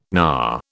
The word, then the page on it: knar: British English